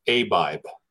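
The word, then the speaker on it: Abib: I just looked it up. It's pronounced Abibe. The Abibe. – month of Abibe. – Okay. Well, I was wrong, and I was wrong again. But I'm sure Google's right, so we'll go with Abibe.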